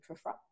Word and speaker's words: Prufrock: Alfred Prufrock.